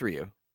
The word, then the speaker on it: Ryu: I thought it's Ru. Really? I thought the proper pronunciation was Ru. – No, it's Ryu. Well, really? – I think it's Ryu.